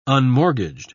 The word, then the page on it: unmortgaged: British English